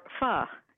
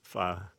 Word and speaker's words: pho: And how do you say it? – Pho. – Pho. Yeah, pho. – I think so. – What the Vietnamese say. Yeah. We went there. – It's like the musical note.